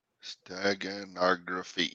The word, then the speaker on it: steganography: Steganography.